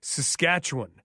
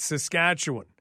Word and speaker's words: Saskatchewan: And it's also Saskatchewan, not Saskatchewan. It's Saskatchewan. And if you say it wrong in either of those places, people are not going to be overly thrilled with you.